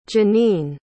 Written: Jeneen

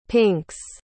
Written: pinks

